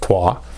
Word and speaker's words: Troyes: The next one here is Troa, which is spelled from an English-American perspective, Troyes. It's pronounced Troa.